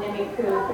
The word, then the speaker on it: Nimiipuu: we work with a group called Nimiqua Protecting the Environment. Nimiqua is the Nez Perce tribe's word for themselves. It's spelled N-I-M-I-I-P-U-U.